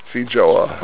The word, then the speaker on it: Feijoa: Feijoa. – How do you say it? – Feijoa.